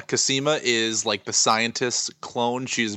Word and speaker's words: Cosima: Cosima? – That's the one.